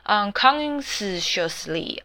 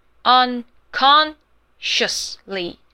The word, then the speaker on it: unconsciously: S 不发音哦，不用去念 unconsciously， no， S 就不用念了，直接接 shus， unconsciously。